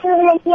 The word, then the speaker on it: Nikki: Hi, this is Nikki, and I think the answer is satin.